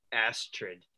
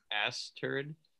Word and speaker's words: Astrid: Anyways, on that show, Jan, the lady who is in a couple seasons, who dates Michael, she names her daughter Astrid. I think Michael like mispronounces it Asturd.